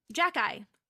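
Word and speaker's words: Xhekaj: And Arbor said, Jacki, just say it backwards.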